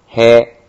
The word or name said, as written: he